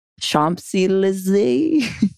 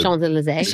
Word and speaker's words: Champs-Élysées: Champs-Élysées. – Well said. – Champs-Élysées. – Champs-Élysées.